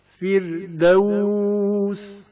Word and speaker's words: فردوس: فردوس فردوس، را سے پہلے زیر ہے اس لیے را کو ترقیق کے ساتھ پڑھا جائے گا.